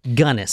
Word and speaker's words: Gunness: This is the Hell's Princess, known as Belle Gunness, G U N N E S S, and from everyone, because I actually looked up how to pronounce her name, and that's what everyone else says her name is. So I guess that's it. Gunnis.